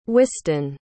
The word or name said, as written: wystan